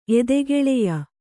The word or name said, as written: ಎದೆಗೆಳೆಯ